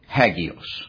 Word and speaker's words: hagios: And that word is hagios. Hagios. H-A-G-I-O-S. Pronounced hagios.